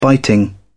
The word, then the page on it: biting: British English